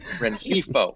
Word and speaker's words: Rengifo: Rengifo.